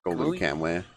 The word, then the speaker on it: Golden Kamuy: Golden Kamuy. – Okay, so I did say it right at first.